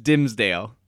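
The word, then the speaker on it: Dimmsdale: I looked it up. It is Dimmesdale. That is how you pronounce it. Because I'm like, I can't be calling him Dimmesdale this whole time if it's like Dimmesdale or something. I look like a jackass, but it's Dimmesdale.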